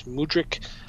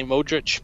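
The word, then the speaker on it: Mudryk: I think it's Mudrik. I keep wanting to say Modric, but I think it's Mudrik.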